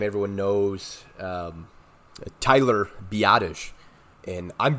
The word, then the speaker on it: Biadasz: I hear it pronounced so many different ways, but last name spelled B-I-A-D-A-S-Z. And I believe the correct way is Biadasz. Biadasz.